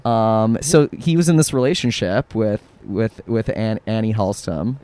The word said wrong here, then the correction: Annie Haslam: Yeah. – Am I pronouncing that right? – Haslam. – Sorry, Annie Haslam.